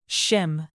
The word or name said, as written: shim